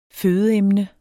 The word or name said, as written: fødeemne